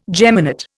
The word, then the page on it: geminate: Indian